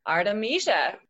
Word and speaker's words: Artemisia: The first one, Artemisia. – Artemisia, yeah. – You got it. – I got it.